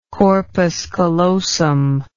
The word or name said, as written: corpus callosum